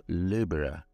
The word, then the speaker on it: Libra: Libra em inglês se escreve da mesma forma, porém fala Libra. Libra. Libra. É bem parecido, né?